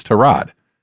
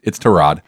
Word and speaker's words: Tyrod: It's Tyrod. And they're like, no, it's Tyrod. And he's like, no, it is Tyrod. You can ask him. Sure enough, they did. And he's like, no, it's Tyrod.